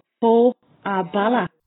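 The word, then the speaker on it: Fág an Bealach: Boa Bala. – Bala Bala. – No, Boa Bala.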